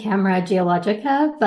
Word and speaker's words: Camera Geologica: I've been saying Camera Geologica, but other people have gone with other options.